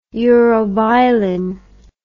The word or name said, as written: urobilin